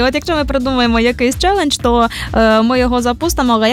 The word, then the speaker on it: челендж: Воно пишеться з однією літерою, з наголосом на першу літеру E. Звичайно, челендж, як чуємо, так і пишемо.